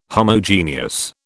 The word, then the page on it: Homogeneous: British English